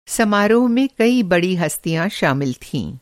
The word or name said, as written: बड़ी हस्ती